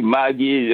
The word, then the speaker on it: Maggi: Maggi. It depends what part of Senegal you are, but both pronunciations are right, and that abuse is so wrong.